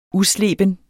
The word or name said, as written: usleben